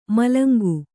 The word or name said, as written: ಮೂಲಂಗಿ